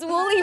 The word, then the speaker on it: woolly: Can't even see the Wally mammoth. My English no good. – So it's woolly? – It's woolly mammoth.